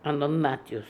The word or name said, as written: anodnatious